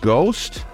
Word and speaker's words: G-Host: Is it Ghost? Am I pronouncing your name right, sir? – It's G-Host. Like I'm a G, but I'm not. I'm a host.